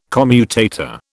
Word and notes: commutator: British English

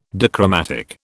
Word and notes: dichromatic: British English

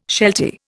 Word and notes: sheltie: Indian

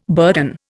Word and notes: burden: Indian

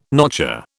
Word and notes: notcher: British English

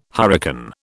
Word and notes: hurricane: British English

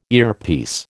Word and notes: earpiece: American English

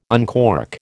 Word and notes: uncork: American English

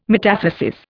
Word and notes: metathesis: Indian

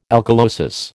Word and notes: alkalosis: American English

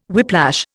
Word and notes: whiplash: Indian

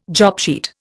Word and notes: jobsheet: Indian

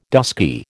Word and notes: dusky: American English

